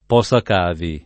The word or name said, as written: posacavi